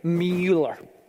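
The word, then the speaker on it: Müller: How do you say it? Yeah, with a yes sound. Okay, Mueller, right?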